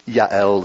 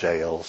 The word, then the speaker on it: Jael: And Jael, you hear me, I keep changing the pronunciation. People pronounce it different ways. Yael is probably the most Hebrew pronunciation, but the thing is, when we sing it, we sing Jael, so that's fine.